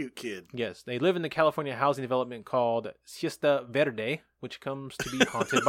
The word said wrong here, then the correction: Cuesta Verde: It's Cuesta, fool. You got to say it like a white guy. – Oh, by Cuesta Verde, which comes to be haunted by ghosts.